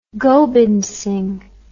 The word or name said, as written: Gobind Singh